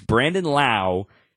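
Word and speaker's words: Brandon Lowe: Remember, it's Brandon Lau and Josh Lowe. Spelt the name, spelt the same, pronounced differently.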